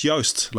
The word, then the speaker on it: Joost: I was born in Holland, and my name is pronounced Joost, like toast with a Y.